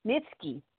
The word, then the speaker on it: Nietzsche: Nietzsche.